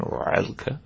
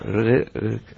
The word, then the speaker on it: Rilke: Maybe if I try to say it in an American pronunciation, Rilke. No, Rilke.